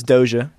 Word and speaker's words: Doja: I'm going to butcher this name. Rogage. – Well, it's Doja.